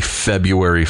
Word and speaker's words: February: And it turns out that I believe you're still supposed to say it without the R. Why would they put the R in there if we're not supposed to pronounce it? But anyway, happy February 14th.